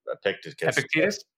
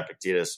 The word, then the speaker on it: Epictetus: It's a great quote from, because I talk about, I can't pronounce his dang name, Epictetus. – Yeah, Epictetus.